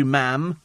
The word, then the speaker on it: ma'am: Thank you, ma'am. Rhymes with jam.